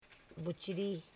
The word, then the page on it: buchiri: bu.chi.ri